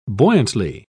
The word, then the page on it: buoyantly: British English